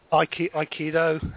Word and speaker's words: Aikido: Aikido.